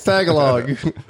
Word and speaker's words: Tagalog: It's Gottlog, right? Gottlog? – Tagalog? – Oh. – Yeah, Tagalog. It's Tagalog.